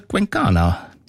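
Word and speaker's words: Cuencana: Ganz einen wunderbaren, tanzbaren Titel hast du ausgesucht, und zwar Chola Quencana, wenn ich das richtig ausspreche. Und da steckt der Name von deiner Stadt drin, oder? Quencana. – Ja, ganz genau.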